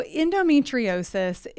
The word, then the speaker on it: endometriosis: So endometriosis, it is a disorder that affects 1 in 10 women in the U.S., but it's not known or talked about nearly enough.